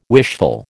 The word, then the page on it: wishful: American English